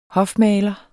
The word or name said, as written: hofmaler